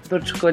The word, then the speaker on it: Brčko: What is it? Brčko? – Brčko is the name of the city. – Oh, did I say it right though? – It's correct.